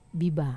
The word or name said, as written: baba